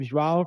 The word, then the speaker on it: João: First of all, you got my name hard right. It's hard to pronounce. I go by Joao, make it easier for everyone.